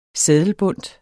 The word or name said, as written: seddelbundt